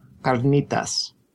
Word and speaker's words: carnitas: Carnitas.